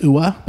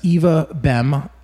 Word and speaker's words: Ewa: His sister, Eva, which by the way is E-W-A. Thank goodness that Google was able to tell me how to actually pronounce that. – Yep. – Because I would have said Ua, but no, it's Eva Bem, and she was on vocals and tambourine and other handheld percussives.